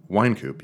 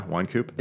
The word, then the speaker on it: Wynkoop: Uh, Wynkoop, yeah, Wynkoop.